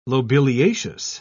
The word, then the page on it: lobeliaceous: British English